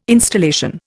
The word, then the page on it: installation: Indian